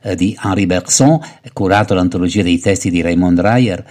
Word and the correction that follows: Raymond Ruyer: e poi La superficie... La pronuncia esatta è esattamente? Raymond Ruyer.